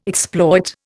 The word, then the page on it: exploit: Indian